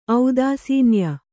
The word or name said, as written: ಔದಾಸೀನ್ಯ